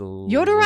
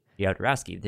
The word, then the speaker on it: Jodorowsky: Apparently, Alejandro Jodorowsky, I don't know how to say his Jodorowsky! – Last name. Jodorowsky, there we go, and Jean Giraud, a.k.a.